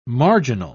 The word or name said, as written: marginal